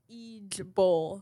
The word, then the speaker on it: IJBOL: What's it? Ijlal? – It's pronounced Ijbol.